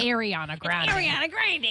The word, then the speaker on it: Ariana Grande: It's Ariana Grande.